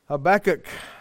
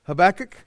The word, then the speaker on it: Habakkuk: Habakkuk, Habakkuk, Habakkuk, whichever way you want to say it. We do believe it is best pronounced Habakkuk.